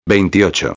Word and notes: 28: espanhol

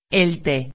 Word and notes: el te: espanhol

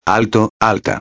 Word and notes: alto-a: espanhol

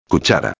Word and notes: cuchara: espanhol

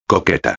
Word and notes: coqueta: espanhol

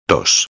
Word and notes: 2: espanhol

